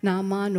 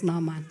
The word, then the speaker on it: Naaman: Naaman or Naaman, but I love to pronounce it as Naaman.